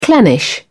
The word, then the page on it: clannish: British English